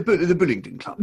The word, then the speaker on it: Bullingdon Club: But you came along with some of your pals from the old, what was it, the Bullingdon? Bullingdon Club? Yeah, how do you say it? – The Bullingdon Club. – The Bullingdon Club, yes.